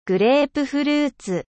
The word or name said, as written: pamplemousse